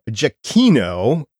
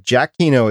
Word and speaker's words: Giacchino: It was done by Michael Giacchino, and I checked this pronunciation, so please, if I'm wrong, let me know what it is. But Giacchino, it's spelled G-I-A-C-C-H-I-N-O.